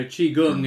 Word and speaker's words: Qigong: So Qigong is the kind of authorized way of saying it, is that right? – Yeah, there are many different ways. I mean, in America, QI, they wouldn't really use or pronounce it. They spell it CHI. So right. That's also Qi, same thing. And yep. Gong is the way the Chinese would pronounce it.